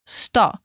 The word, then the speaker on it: sto: De novo, agora atenção à pronúncia de sto, com S mudo, sto, e così, com ênfase no z.